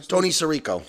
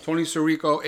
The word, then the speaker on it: Tony Sirico: Tony Sirico. – Tony Sirico, a.k.a.